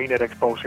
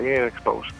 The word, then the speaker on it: Inner Spouse: En inglés sería Inner Spouse igualmente, o sea, es más o menos como se lee, como se escribe, es como se pronuncia, Inner Spouse.